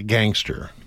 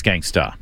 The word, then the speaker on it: gangsta: My wife is a gangster. – Yeah. – I believe it's pronounced... – She's a gangsta. – It's gangsta.